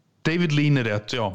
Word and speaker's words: David Lean: Du får nog uh, uttala det rätt en gång Robert. – Uh, Okej, okay. vad heter han då som eh, regisserade just Lawrence of Arabia? David Lean. David Lean, okej.